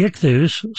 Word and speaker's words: ichthus: In Greek, the word for fish is spelled I-C-H-T-H-U-S, I-C-H-T-H-U-S.